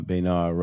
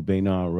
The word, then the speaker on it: Bayard: did a fabulous job playing Bayard Rustin. For those of you who don't know, Bayard Rustin was the It's Bayard.